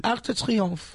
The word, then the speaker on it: Arc de Triomphe: Arc de Triomphe.